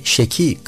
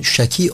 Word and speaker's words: şaki: Bunlardan bir tanesi de Said ve Şeki kavramları. Türkçe'de bunun Şaki olarak telaffuz edildiğini hatırlayalım.